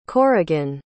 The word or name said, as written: korrigan